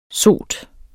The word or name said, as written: sot